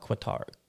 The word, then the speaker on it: Qatar: Education City Golf Club in Doha, Qatar.